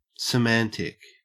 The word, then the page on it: semantic: Australian English